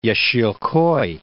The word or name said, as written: Yesilkoy